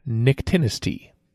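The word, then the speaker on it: nyctinasty: Nictinesty. Could you pronounce it for me again? – Nictinesty.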